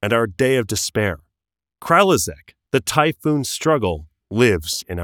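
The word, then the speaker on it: Kralizec: This word. Kralizek. – Yeah. – Really? – Think that's right. That's how they say it in the audiobook.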